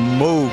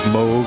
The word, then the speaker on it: Moog: technically it's the Moog synthesizer, like Moog, Rogue, Vogue. But many people pronounce it Moog.